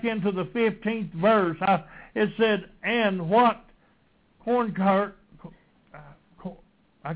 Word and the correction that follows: Concord: Concord have Christ with Belial?